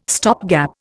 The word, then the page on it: stopgap: Indian